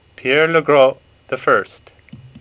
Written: Legros

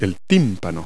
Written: eardrum

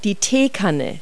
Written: teapot

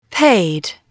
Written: paid

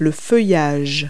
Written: foliage

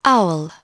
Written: owl

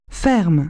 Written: farm

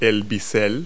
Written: splay

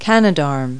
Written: Canadarm

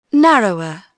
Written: narrower

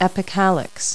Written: epicalyx

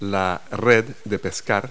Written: fishnet

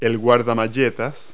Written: valance

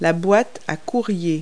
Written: desk tray

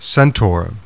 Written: CENTAUR

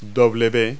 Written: w

